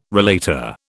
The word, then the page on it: relater: British English